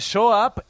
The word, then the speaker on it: soap: Vale, pues corregido esto, es verdad que soap es jabón en inglés, pero ¿cómo se pronuncia? – Jabón en inglés. Soap.